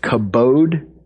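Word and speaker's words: kabod (כבוד): Now, in the verb form, it's pronounced kabed. In the noun form, it's pronounced kabod, but it's the same word.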